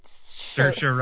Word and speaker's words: Saoirse: Saoirse rhymes with inertia.